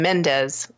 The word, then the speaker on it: Mendes: and Sam Menendez for 1917. And Sam Sam Mendes, sorry, I added a syllable to his name.